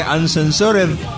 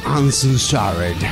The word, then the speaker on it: Uncensored: Digo, el pronunciar en inglés no es lo mío, pero estoy seguro que Uncensored debe estar cerca de ahí, pero... – Uncensored. – Ahí está mejor.